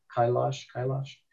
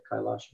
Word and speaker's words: Kailash: And it's golden Mount, I forgot the name of the mountain, Kailash. There's different ways of pronouncing it, Kailash.